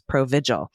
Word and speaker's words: Provigil: Provigil is pronounced like vigilance, Provigil, hence why it's called Provigil.